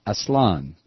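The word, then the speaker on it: Aztlán: Bruce writes, Aslan is a bitch to pronounce, but every A in the Spanish language is always pronounced the same, like casa. Take the A sound from casa and use it twice for the two A's. It's Aslan, with the stress on the final syllable, noted by the accent mark.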